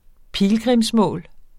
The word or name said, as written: pilgrimsmål